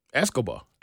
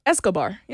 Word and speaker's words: Escobar: Pablo Escobar? Am I saying that right? – Escobar. – Escobar, yeah.